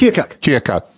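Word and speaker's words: Keokuk: But it's Kia Cook, right? – No, Cuck. Like hockey puck. – Kia Cuck.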